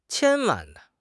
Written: qian1 wan4

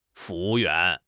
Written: fu2 wu4 yuan2

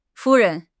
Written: 夫人

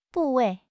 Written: 部位

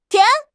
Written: tian2

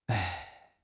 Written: ai1

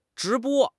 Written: 直播

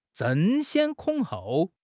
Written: 争先恐后

